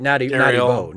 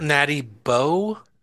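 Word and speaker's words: Natty Boh: Oh no, not natural light, Natty Bow. Not Natty Light, Natty Bow. Natural National Bohemian. That's our Maryland beer. – Natty Bow? – Natty Bow.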